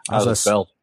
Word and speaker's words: Azaceta: Image Comics has Black Science number 13, Chew number 49, Deadly Class number 13, Invincible 120, Outcast by Kirkman and Azi Sieta. I forget how to say his name. – Azaceta. Azaceta? – There you go.